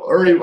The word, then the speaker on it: Uri: And you know, it's pronounced Uri, not Yuri. I was sorry to get that messed up because I've got some friends who pronounce Yuri, but it's Uri.